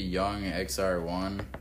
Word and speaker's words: YXNGXR1: I think it's supposed to be Young XR1. But it's spelled YXNGXR1, and he I fuck with him, man.